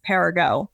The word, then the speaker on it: Perigo: So I always tell people, don't make it too complicated. It's just Perigo. Perigo.